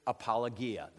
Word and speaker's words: apologia: And that is the word apologia. That's the Greek word, apologia. This is the foundational verse that virtually every reputable apologetic starts with right here, okay? Apologia, that's the English pronunciation there.